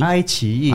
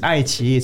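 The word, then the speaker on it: iQIYI: Is that how to pronounce it? Ai Qi. – Ai Qi. – Ai Qi. – Oh, Ai Qi. – It's Ai Qi. So Ai Qi. Yeah, that's how you pronounce it in the Chinese wording.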